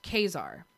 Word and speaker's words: Caesar: Oh, and Caesar is actually pronounced Kazar.